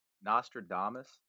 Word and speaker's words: Nostradamus: Nostradamus.